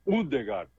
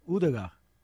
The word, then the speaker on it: Ødegaard: Aí é Ødegaard. – Odegaard. – Porque aqui tem a diferença, né? O cortado no meio, né? Aí ele tem esse som de U. Assim, a pronúncia.